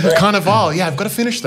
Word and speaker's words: Cannavale: I just realized I pronounced that wrong at the start. I said Cannavale.